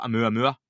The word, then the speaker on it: Oumuamua: They talked about Oumuamua, is how you pronounce that.